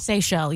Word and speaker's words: Seychelles: Is it pronounced Seychelles? – Seychelles, yeah. – The Seychelles, the islands? – S-E-Y-C-H-E-L-L-E.